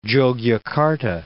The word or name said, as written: Jogjakarta